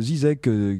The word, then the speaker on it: ZZK: Qui est une artiste argentine donc qui est le buzz du moment à cause du label Zizek qui s'écrit ZZK.